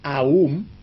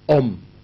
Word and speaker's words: Om: Hay la pronunciación Aum, A-U-M, y la pronunciación Om.